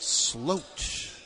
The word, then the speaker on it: Sloot: Spelled S-L-O-O-T, but pronounced Sloat.